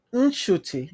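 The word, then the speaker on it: Ncuti: Inkut Gatwa, como é que chama? Como é que fala? Sabe pronunciar? – É Chute. Inchute.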